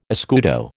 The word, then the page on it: escudo: American English